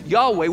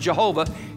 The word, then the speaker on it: Yahweh: It's only as man and woman come together, stamped upon them is Yahweh, yod, hey, vav, hey, Yahweh. We mispronounce it Jehovah.